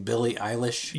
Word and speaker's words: Billie Eilish: Billie Eilish? – Yeah.